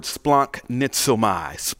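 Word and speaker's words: splanchnizomai: Compassion is the Greek word splanknizomai. Splanknizomai. S-P-L-onknizomai. That's how you spell it, okay?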